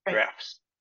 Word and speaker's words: draughts: It's spelled draughts, but it's called draughts, which comes from the Old English word drogan, which means to move, pull, or draw.